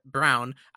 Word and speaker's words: Braun: One quick shout-out, even though he went to Kansas, Christian Brown. I have to respect the enunciation, as Jeff Van Gundy said. I thought it was Braun, too.